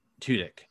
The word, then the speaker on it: Tudyk: Tudyk.